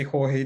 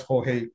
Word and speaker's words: Jorge: Yeah, some people say Joe, some people say Jorge, depending if you could pronounce Jorge correctly. Some people put the accent in the wrong place.